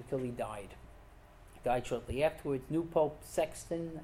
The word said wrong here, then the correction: Sixtus: Sixtus the Fourth.